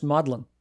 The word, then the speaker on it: Magdalene: And the name of the university is pronounced Maudlin. – Oh. – It's a college in Cambridge, and it's also a college in Oxford, and both are pronounced Maudlin in British usage.